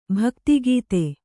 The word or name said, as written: ಭಕ್ತಿಗೀತೆ